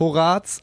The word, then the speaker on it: Horaz: Wenn ihr mal auf Horaz 886 gehen wollt, Betonung auf Horaz, ganz wichtig. Jetzt ja. immer falsch ausgesprochen.